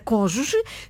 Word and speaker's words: cônjuge: Portanto, pronúncia correta, cônjuge. Escrita correta com circunflexo.